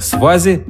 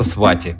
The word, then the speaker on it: свати: Главная разница в произношении – там, где свати четко выговаривают «т», зулу скажет «з», например, «свази» вместо «свати».